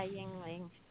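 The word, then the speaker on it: Yuengling: You have Great Lakes Brewing out of Cleveland, and you have how is it pronounced? Yingling? – Yeah, Yingling.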